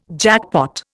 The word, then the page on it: jackpot: Indian